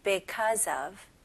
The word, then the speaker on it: because of: 네, 여기에서 be로 시작하니까 because 이렇게 발음하시는 분들이 있는데, 물론 그것도 되게 정확한 발음이지만, 원어민들은 그렇게 발음하기 조금 힘드니까, 오히려 be보다는 be, because of. because of. 네, 이런 식으로 발음합니다.